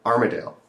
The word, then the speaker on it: Armidale: I met you in Armidale.